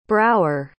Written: brougher

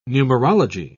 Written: numerology

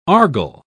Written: argol

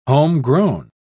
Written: homegrown

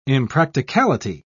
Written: impracticality

